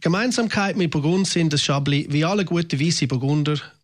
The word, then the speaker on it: Chablis: Man trinkt vermehrt wieder Weisswein und heute da reden wir über einen der bekanntesten Weisswein der Welt, nämlich den Chablis Radio 1 wein Wo kommt der eigentlich her? – Chablis geschrieben, aber Chablis ausgesprochen.